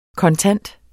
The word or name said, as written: kontant